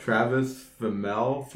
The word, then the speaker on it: Travis Fimmel: I don't know if I'm going to say this right, but Travis Fimmel from Warcraft.